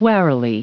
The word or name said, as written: warily